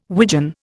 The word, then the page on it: widgeon: Indian